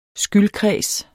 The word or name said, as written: skyldkreds